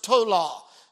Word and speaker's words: towla: The word worm here is pronounced tola, T-O-W-L-A.